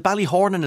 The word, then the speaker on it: Ballyhornan: I only discovered, is it Ballyhornan? Is that how you pronounce the place? – Absolutely right.